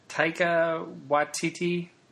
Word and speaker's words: Taika Waititi: But and I never can pronounce his name right. Taika Waititi? – Yes. – Is that close? – Nailed it. Good job.